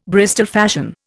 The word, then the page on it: Bristol fashion: Indian